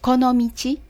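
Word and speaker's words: Konomichi (この道): This is Konomichi. Did I say it right? – Konomichi.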